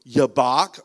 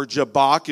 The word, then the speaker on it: Jabbok: a river, Yabbok, or Jabbok, if we would say it in English.